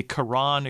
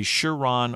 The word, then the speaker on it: Charon: By the way, it's acceptable to pronounce the CH in Charon as sh, ch, or even a K sound. So it could be Charon, it could be Charon, or it could be Charon.